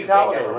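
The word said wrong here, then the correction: Savile: I said Saladay. I fucking pronounced his name wrong. Seville. Jimmy Seville.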